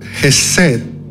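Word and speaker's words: Hesed (חסד): En el idioma original, en hebreo, existe una palabra que se pronuncia Hesed.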